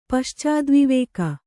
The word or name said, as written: ಪಶ್ಚಾದ್ವಿವೇಕ